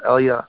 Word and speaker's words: Eliyahu: We know it's Eliyahu, but it also comes through as being pronounced Elia.